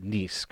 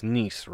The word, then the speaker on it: Nice: I believe we pronounce it Nice, correct? Like, if we're not being funny, it's Nice, right? Nice? It's correct. – Not nice.